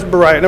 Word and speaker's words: Beriah: I'm sure that's Beacon, is how you pronounce that. I'm sorry, it's Beriah, never mind.